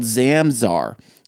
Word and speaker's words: Zamzar: Okay, it's called Zamzar. It's spelled Z-A-M-Z-A-R. Zamzar. – And how do you spell it? Z-A-M? – Z-A-R. – Z-A-R. Oh, Zamzar.